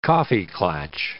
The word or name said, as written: coffee-klatsch